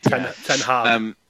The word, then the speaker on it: Ten Hag: Ten Hag, is that how you say it? – Ten Hag.